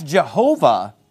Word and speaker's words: Jehovah: Now, that's Jehovah that... L-O-R-D, that what's known as the Tetragrammaton, the four-letter Old Testament name of God that we pronounce in English as Jehovah.